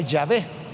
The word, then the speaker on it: Yahvé: Hoy en día, hermanos, que hay análisis lingüísticos muy minuciosos, se sabe de que lo más probable, lo más probable es que la pronunciación del nombre de Dios era era Yahvé.